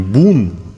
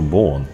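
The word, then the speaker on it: Boon: Э, меня один знаток э, пива как-то поправил, что э, это не бун, как на английский манер читается, да, Абоон, потому что фамилия у него Боон.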